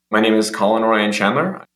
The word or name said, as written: Colin Chandler